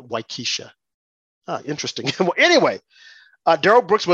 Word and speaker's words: Waukesha: And I think on his gun, he had one of the victims' names from Wakasa, Wisconsin. And I'm probably mispronouncing that name. Wakasha, Wisconsin. – Waukesha.